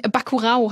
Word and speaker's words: Bacurau: Bacurau heißt er, wenn er portugiesisch richtig ausgesprochen ist.